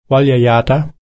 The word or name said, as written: walyo-yerta